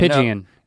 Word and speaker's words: pigeon: And pigeon. – When you look at this, you see right here, it's very clearly a two syllable word, pigeon. Pigeon. Pigeon is the correct pronunciation. – Now, P-I-J upside down E-N.